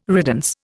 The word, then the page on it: riddance: Indian